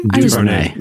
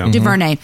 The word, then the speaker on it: DuVernay: But just recently, Netflix announced that Colin and filmmaker Ava DuVernay, is that how you say her name? – DuVernay. – DuVernay, which she's responsible for some amazing films.